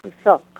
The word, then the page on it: kusok: Ninilchik Russian